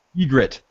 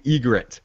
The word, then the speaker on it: Ygritte: Ygritte, thank you, Ygritte.